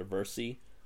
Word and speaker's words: VRSC: It's a VRSC headset, although in the video they called it a Versi, which is really weird. I would have never assumed that you actually pronounce it like that.